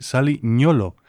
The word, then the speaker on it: Nyolo: Y me llamó la atención cuando un periodista catalán pronunció su nombre así, Sally Nyolo, porque se escribe con una N y una Y, y en el idioma catalán valenciano, pues eso es el sonido ñ. Y digo, bueno, pues lo pronunciará a su manera. Y resulta que es que se pronuncia así, en las lenguas de esas tierras del África ya ecuatorial, prácticamente de Camerún.